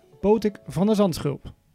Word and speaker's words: Botic van de Zandschulp: In het buitenland kunnen ze zijn naam niet uitspreken, maar dat moet mij met mijn harde G wel lukken. Tegenover mij zit Botik van der Zandschulp.